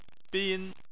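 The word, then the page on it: bin: tone 1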